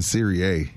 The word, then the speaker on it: Serie A: Is that how you say it? I've been saying Serie A. – Just A in Italian is A.